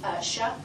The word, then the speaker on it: Shuck: Shuck. – Spell it. – S-H-U-C-K.